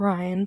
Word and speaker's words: Rian: which is Ryan, but it's spelled R-I-A-N, autocorrects to rain sometimes, which I think is funny.